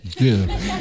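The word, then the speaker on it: Győr: A když jezdím do Děru, tak tam bych to měl vyslovovat. – Děr. – Do Děru? Děru. Tak tam bych to měl vyslovovat, tak jak to říkám, nebo oni... – No, tak, tady... jak to říkáte, to je dobrý.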